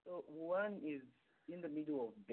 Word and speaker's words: Wuhan: So Wuhan is spelled W U, an umbrella, and then H A N. – Okay, Wuhan.